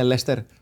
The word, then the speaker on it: Leicester: Είναι το ίδιο γαλλικό, που γιατί ενώ γράφεται Leicester, το προφέρετε στην Αγγλία Leicester.